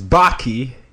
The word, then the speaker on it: Bakke: Today, I have with me Chris Bakke. Actually, he rhymes with hockey.